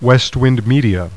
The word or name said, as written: west